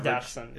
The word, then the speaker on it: Dachshund: And yes, it's pronounced Dachshund.